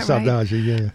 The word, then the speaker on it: saudade: Yeah, saudade, yeah. – That permeates this book, and it's in several of these essays. So talk a little bit more about that. – Saudade is you're missing something.